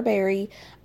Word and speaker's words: Berreigh: Welcome to another episode of Everyone's Business But Mine with me, Kara Berry. That is spelled B-E-R-R-E-I-G-H.